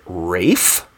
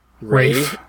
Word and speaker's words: Ralph: Kind of like how Ralph Fiennes pronounces his name Rafe? – Rafe?